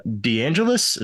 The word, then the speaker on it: DeAngelis: And without further ado, let's hop into this episode with Gina DeAngelis. Everyone, we are back with a new episode. And today on the show, we have a very special guest, Gina DeAngelis.